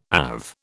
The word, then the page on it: AV: British English